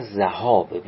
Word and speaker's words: ذهاب: و شکفی بقای الحرمه، شک بکنیم در بقای حرمت بعد ذهاب سلسین یا سلسین، به هر دو وجهش میشه خوند، اما ذهاب رو هیچگاه به کسر زاد نخونید، هرچند در کلمات ما گاهی اوقات به اشتباه این گونه تلفظ میشه. ذهاب جمع ذهبه، مصدر ذهب ذهاب به فتح زال.